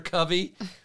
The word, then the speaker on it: Covey: Under Covey, rhymes with Lovey.